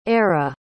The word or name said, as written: eire